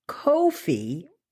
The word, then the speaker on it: Ko-fi: But this one is called Ko-fi. It's spelled K-O-F-I.